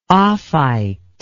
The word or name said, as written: ophite